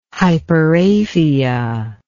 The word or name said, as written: hyperaphia